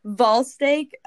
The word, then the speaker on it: Vuylsteke: Vuylsteke.